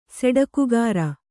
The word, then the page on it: ಸೆಡಕುಗಾರ: Kannada